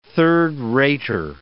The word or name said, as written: third-rater